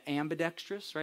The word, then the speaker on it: ambidextrous: Ambide- ambidextrous, right? Is that how you say it? Ambidextrous right? Is that right? It always sounds like amphibious to me.